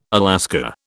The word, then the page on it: Alaska: British English